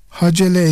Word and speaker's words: hacele: Hacele ise H ve Cim harfleri fethalı olarak telaffuz edilir. Sahih ve meşhur söyleyiş budur. Bundan maksat ise Hical'in çoğuludur.